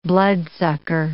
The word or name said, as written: bloodsucker